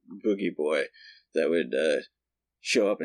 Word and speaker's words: Boojie: Yeah, Boogie Boy is B-O-O-J-I-E, but I feel like you pronounce it Boogie.